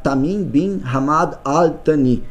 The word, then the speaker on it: Tamim bin Hamad Al Thani: Tá o acordo recíproco foi assinado durante o encontro com o emir do Catar, Tamim bin Hamad Al Thani, nesta segunda. – Uau! pronúncia tá certa, né? Corretíssima pronúncia do árabe, parabéns.